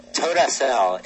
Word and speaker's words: Todacell: Did I pronounce that right? Toda? – Yeah. Todacell. It's Hebrew for thank you, and then sell.